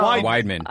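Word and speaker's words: Weidman: You said his name wrong. – She will fight you. – I just. – Yoel? – No. – Wideman. – I don't care about Yoel Romero. You can say his name wrong. – Yeah. Wideman? Is that how you say it? – Yeah.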